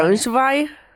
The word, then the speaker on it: Braunschweig: El 26 de julio del 84, un estudiante de informática de 24 años de Braunschweig, espero haberlo dicho bien, en alemán Braunschweig.